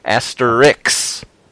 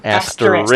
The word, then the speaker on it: Asterix: But I like that our old friends Tintin and Asterix were in there, which of course I mispronounced. I think I just mispronounced it there. Asterix. Not asterisk.